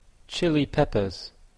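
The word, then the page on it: chili-peppers: Canadian English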